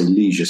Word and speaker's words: leisure: And the industry that first came up was what we would pronounce in the UK as leisure studies. I think you guys say leisure studies.